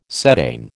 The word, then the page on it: cetane: American English